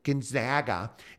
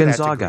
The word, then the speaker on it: Gonzaga: I want you to say it right. It's Gonzaga. I paid for Gonzaga. That degree. And you'll respect my authority. It's Gonzaga. – Gonzaga.